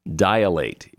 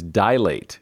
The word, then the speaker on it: dilate: Dilate is an example. There's only two syllables in that word. It is dilate, not dilate.